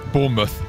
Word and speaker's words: Bournemouth: Bournemouth. – Bournemouth. – Bournemouth. – Bournemouth. Bournemouth. – Bournemouth. – Not porn mouse.